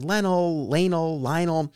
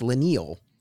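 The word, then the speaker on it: Leinil: how do you pronounce the artist's name? Because I've heard Lionel. And according to Mark Waid, it's Lenil. And that was the pronunciation that we went with in the episode.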